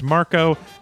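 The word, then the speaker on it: Marko: Just to catch everybody up, the main character's name is Mark, which in Esperanto is pronounced Marco,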